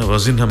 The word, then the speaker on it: Vozinha: Vozinha. – Vozinha. All right. – Yeah, Vozinha, my man.